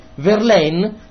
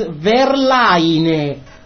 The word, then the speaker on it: Verlaine: In the Italian way, Verlaine, no. The real pronounce is Verlaine.